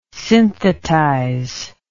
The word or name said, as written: synthetize